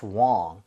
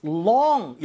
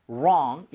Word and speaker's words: wrong: 이럴 때는 이제 what's wrong 가능하겠죠? 자, 이거 발음할 때 아시죠, 여러분? what's long 이래버리면 길다라는 뜻이기 때문에 wrong, 입 모양을 모아 주셔야 됩니다.